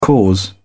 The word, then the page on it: cause: British English